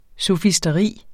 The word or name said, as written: sofisteri